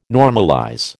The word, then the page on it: normalize: American English